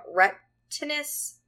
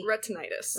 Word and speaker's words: retinitis: she suffers from retinitis pigmentosa, is that how you pronounce it? – Yep. – And that's exactly what Trinity... – Retinitis. – Retinitis. That's correct.